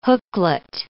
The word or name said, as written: hooklet